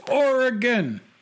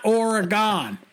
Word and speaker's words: Oregon: It's pronounced Oregon, not Oregon, or Ergon, or Ergun.